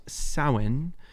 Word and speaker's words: Samhain: Really odd pronunciation for the spelling. If you look it up, it's spelled Samhain. – Yeah, I know. It's not how I would have thought it was pronounced. – Same. It's definitely not phonetic, but the Celts just do what they want. Yeah, it's pronounced Samhain,